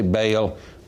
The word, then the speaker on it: Baal: Now, we say Baal, Baal is really the correct pronunciation, right.